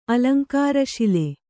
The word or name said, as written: ಅಲಂಕಾರಶಿಲೆ